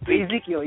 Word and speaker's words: Ezekiel: I can't pronounce his name, Ezel e- or something like that. But Ezekiel, know, if y'all listen, they call him Ezekiel.